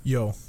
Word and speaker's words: Yeo: And then Yeo. On the Blues? – Yo, I think it's pronounced yo.